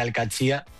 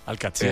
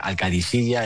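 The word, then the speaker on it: Al-Qadsiah: Bueno, se suele decir Alcachía. – Alcachía. – Pero también hay gente que dice Alcadisilla. Es que es difícil, ¿eh? Ah. No es fácil, pero Alcachía es lo más fácil.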